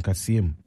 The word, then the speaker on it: Kasem: In Kasem, Kasem residents or in Kasem? – Yeah, in Kasem. – Maybe you should stress on the M.